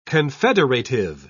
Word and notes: confederative: British English

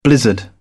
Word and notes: blizzard: British English

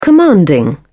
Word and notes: commanding: British English

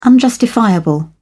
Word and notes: unjustifiable: British English

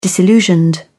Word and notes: disillusioned: British English

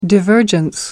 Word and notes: divergence: American English

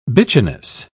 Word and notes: bitchiness: British English

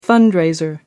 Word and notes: fundraiser: American English

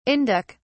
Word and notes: Indic: British English

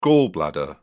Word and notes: gall bladder: British English

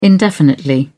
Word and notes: indefinitely: British English

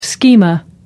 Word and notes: schema: American English

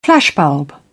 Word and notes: flashbulb: British English

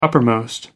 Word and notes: upmost: American English

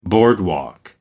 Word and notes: boardwalk: American English

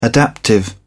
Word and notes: adaptive: British English

